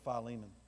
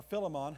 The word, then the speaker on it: Philemon: Or Philemon, however you were taught to pronounce it as a kid, which again, I found out that it's okay to pronounce it either way. Whether you're a scholar or not a scholar, Philemon, Philemon, it's all good.